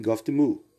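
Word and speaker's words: Gov't Mule: Mule, Goft Mule. É uma, uma pronúncia bem estranha. Seria uma abreviação de Government Mule, que é governo, governo mula, governo burro, governo estúpido, governo idiota.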